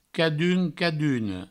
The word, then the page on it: cadun, caduna: cadun, caduno